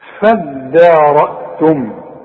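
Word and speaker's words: فادارأتم: فدارأتم دي عايزة منك فن في النطق ما تقولش فض صد الدال الدال والمد بتاعها مرقق هتقول فداء عارف على وزن ايه فدان شوف فدان ارض فدان شوف الدال هتقول لي ايه فضان ولا تقول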